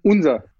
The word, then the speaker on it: Unzer: Wie spricht man diesen Namen eigentlich aus? Unser oder Junzer oder Anser? Unser. Unser? – Ja.